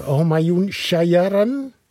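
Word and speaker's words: Homayoun Shajarian: Nu zijn we aangekomen bij zanger Homayoun Shayaran.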